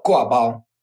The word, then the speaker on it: gua bao: Oh, gua bao.